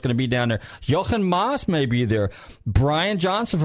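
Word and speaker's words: Jochen Mass: He's a German. Jochen Maas. Okay. – Yeah. – That's how you pronounce it.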